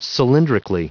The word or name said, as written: cylindrically